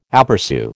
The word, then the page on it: aperÇu: American English